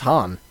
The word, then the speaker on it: Han: How do you say this person's first name? – Oh, okay. – Han? – Yep. Well, let's just go around the room. – Han. – So, it's Han.